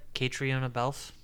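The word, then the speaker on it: Caitríona Balfe: Catriona is how you pronounce her name? – Is it? – Catriona Balf. – Catriona Balf. Huh. Good job.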